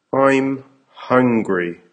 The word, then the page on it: I'm hungry: American English